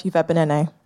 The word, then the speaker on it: Uviebinené: I'm Elizabeth Uviebinené.